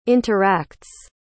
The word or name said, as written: interacts